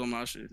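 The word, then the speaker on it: Omashu: You said it right. – Yeah, it's Omashi.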